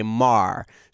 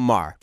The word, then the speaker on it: Maar: he actually announced this week that he actually goes by Mar. So M-A-A-R, just call him Mar.